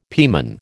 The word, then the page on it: pieman: American English